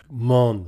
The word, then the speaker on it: moan: Interesting also is the choice of moan, which, incidentally, here as in other instances, would in Shakespeare's pronunciation have had a perfect rhyme with gone. Mon and gone would have sounded very similar in a Midlandish accent at the time.